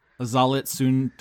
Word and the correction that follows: Xolitzcuintli: Oh, fuck. Fuck me. It's Xolitzcuintli.